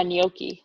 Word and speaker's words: gnocchi: Wait, is that the right way to say it? Yeah, gnocchi. – gnocchi. Yeah, Gnocchi. Gnocchi. Yeah. Gnocchi. Pasta.